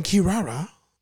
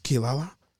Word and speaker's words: Kirara: Inuyasha and Kirara, which is hilarious because in the American translation, it's always pronounced Kilala.